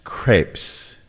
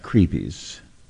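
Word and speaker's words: crêpes: I believe it's pronounced crepes, not creepies. – It is spelled. – Yeah. – C-R-E-E-P-I-E-S.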